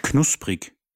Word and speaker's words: knusprig: Knusprig. – How do you spell that? – K-N-U-S-P-R-I-G.